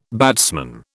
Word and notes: batsman: British English

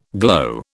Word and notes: glow: British English

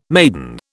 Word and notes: maidan: British English

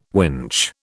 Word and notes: winch: British English

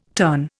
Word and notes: turn: Indian

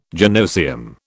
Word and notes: gynoecium: British English

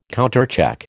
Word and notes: countercheck: American English

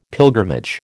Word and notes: pilgrimage: American English

